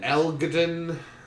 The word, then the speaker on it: Elgton: Elgton. How do you say that? The G is silent right now.